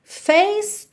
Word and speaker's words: face: E depois dos solos e de voltar ao refrão, Face to face, atenção aí como você não pronuncia os es do final, ok? Face to face, each classic case, each classic case, case também você não pronuncia o e do final.